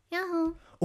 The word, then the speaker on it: 야호: 네, 야호. – Okay, so you have to soften the pronunciation when saying this. Instead of going yahoo, all serious, you gotta soften that end part a bit and go yahoo.